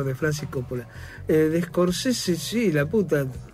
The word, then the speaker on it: Scorsese: Nos vamos a centrar en el ciclo de Scorsese, de Martin Scorsese, como se debe pronunciar.